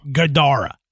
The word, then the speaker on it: Gadara: And this time, he said that it spoke a three-syllable word that sounded like Gadara, with the second syllable emphasized, Gadara.